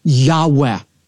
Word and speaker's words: Yahweh: It's known as the Tetragrammaton, and most Semitic scholars believe it's pronounced a breathy, Yahweh.